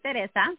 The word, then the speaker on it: Teresa: My name is Teresa. I actually pronounce it Teresa. I know it's hard for many.